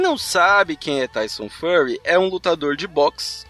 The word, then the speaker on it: Fury: Easy, a pronúncia dele é Fury, é Fury.